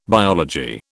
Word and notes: biology: British English

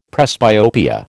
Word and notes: presbyopia: American English

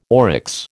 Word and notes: oryx: American English

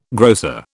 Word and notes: grocer: British English

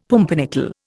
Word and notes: pumpernickel: Indian

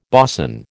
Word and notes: boson: American English

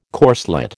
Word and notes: corslet: American English

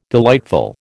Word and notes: delightful: American English

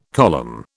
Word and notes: column: British English

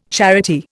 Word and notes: charity: Indian

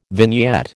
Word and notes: vignette: American English

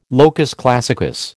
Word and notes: locus classicus: American English

